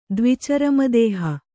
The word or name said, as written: ದ್ವಿಚರಮದೇಹ